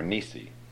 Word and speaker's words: Nissi: But it's Yahweh Nissa, or Nissi.